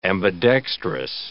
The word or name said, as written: ambidextrous